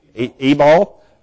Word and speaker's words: Ebal: Ebal.